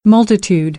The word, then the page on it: multitudinous: American English